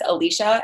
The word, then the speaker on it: Alisha: And it was like, okay, so my name is Alisha, and it's A-L-I-S-H-A. But people say Alisha a lot because that's how it's spelled. And I'll respond to it, but it's not right. Like, it's not quite my name.